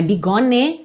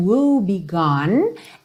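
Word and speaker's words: woebegone: Também não é bigone, cuidado que esse afinal não é pronunciado. Então, woe be gone and so restless nights.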